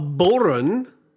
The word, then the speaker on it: bodhran: A boron is what type of instrument? – Oh, a boron.